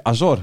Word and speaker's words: Azor: Oké, eh, Azor, eh, moet ik Azor. Hij deed de klemtoon anders. Ik zeg altijd Azor, maar het is Azor.